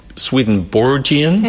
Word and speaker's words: Swedenborgian: I have so many questions, okay, as many people do, about what Swedenborgian is the right pronunciation.